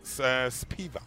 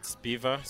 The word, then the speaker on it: Sphiva: So it is Spiva. – Spiva. – Spiva. Spiva. Spiva, yeah. Mm. – So it's pronounced Spiva, spelled S-P-H-I-V-A.